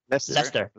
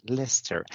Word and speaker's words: Leicester: Now, then we have this SharePoint Saturday from Leicester. – We're having a chat on Leicester. Why do you add letters on the words if you don't pronounce them?